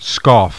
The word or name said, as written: scoff